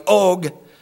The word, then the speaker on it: Og: In verses 33 to 35, we read, then they turned and went up by way of Bashan and Og, and that's how you pronounce that if my Hebrew is right, Og.